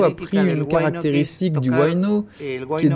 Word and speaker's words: huayno: qu'on prononce huayno en Bolivie, ou le carnavalito qui lui ressemble beaucoup.